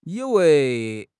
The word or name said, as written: yi3 wei2